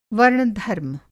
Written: वर्ण-धर्म

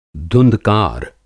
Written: धुँधकार

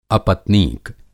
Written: अपत्नीक

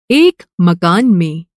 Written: मकान

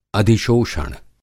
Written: अधिशोषण

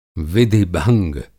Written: विधि-भंग

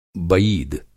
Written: बईद

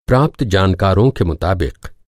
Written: जानकारी

